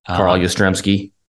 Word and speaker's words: Yastrzemski: Carl Yastrzemski.